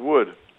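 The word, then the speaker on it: Wodehouse: It's pronounced Wood. – I know it's pronounced Wood.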